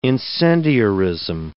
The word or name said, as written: incendiarism